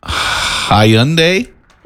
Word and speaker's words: Hyundai: 217, I'm sorry, saying now driving a Hyundai.